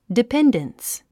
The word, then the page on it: dependence: dipéndəns; ディペンデンス